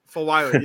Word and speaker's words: Fulwiley: Raven leading the way at the point guard position, but obviously Malaysia Fulwiley, I believe is how you say her last name. Fulwiley, yeah.